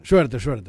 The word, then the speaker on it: Schwerter: Schwerter. – Ese mismo. – Vos tenés que pronunciarlo bien, Schwerter. Porque eso es todo alemán. – Schwerter.